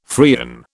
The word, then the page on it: Freon: British English